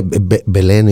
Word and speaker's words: Belén: Am I pronouncing it well? – Yeah, Belén is Bethlehem in Spanish.